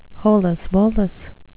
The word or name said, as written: holus-bolus